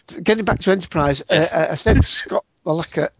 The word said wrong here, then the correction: Bakula: I can't pronounce Bakula.